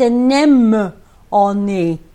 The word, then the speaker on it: anemone: The thing is, why can hardly anyone pronounce anemone? It's not anemone. It's anemone, not anemone. It's a nem on me.